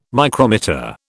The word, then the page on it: micrometer: British English